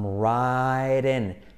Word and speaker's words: writing: Bueno, porque la W no se pronuncia, ¿vale? So, estoy escribiendo una carta. I'm ra, ra. Como ra, ra, Rasputin. I'm writing.